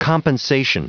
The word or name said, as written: compensation